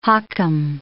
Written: hakim

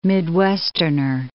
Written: Midwesterner

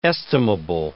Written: estimable

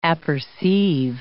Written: apperceive

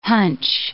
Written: hunch

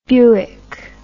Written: Buick

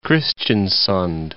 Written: Kristiansund

